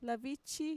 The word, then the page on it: lavichi: Mixtec